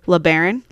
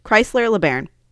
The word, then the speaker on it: LeBaron: And then they go to the clown just goes to the two-door Chrysler LeBron, or is that how you say it? – I have no idea. – LeBaron, Chrysler LeBaron. When the, I'm going to have trouble say this car name.